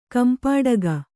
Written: ಕಂಪಿಡಿಗು

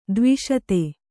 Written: ಧ್ವಸ್ತ

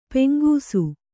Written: ಪೆಂಗುಸು

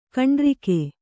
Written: ಕಂಡ್ರಿಕೆ

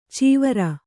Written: ಚಿವುರು

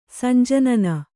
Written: ಸಂಜನನ

